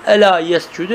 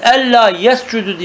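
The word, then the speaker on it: Ela yescudu (ألا يسجدوا): Yescudu diye mi okunuyor? – Ya yescudu. – Ela yescudu. – Ela yescudu. Yescudu ya kavmi uscudu. O emzeyi vasıl düşüyor. İşte. Bir de onun şey vardır. Ella yescudu diye okuyan vardır.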